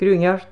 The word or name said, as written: Gruinart